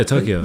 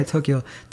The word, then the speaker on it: Tokyo: Oh, yeah, Tokyo. – Tokyo.